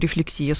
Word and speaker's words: рефлексия: Мы именно психологи говорим рефлексия с ударением на последний слог.